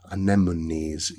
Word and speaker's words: anemones: Then little details like sugared anemones, anemones, yes, pronounce that properly.